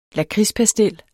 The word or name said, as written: lakridspastil